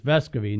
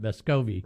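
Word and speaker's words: Vescovi: You know, the pronunciation now is Vescovy, not Vescovy.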